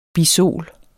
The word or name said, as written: bisol